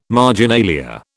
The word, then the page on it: marginalia: British English